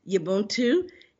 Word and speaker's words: Ubuntu: Yes, the word is Ubuntu,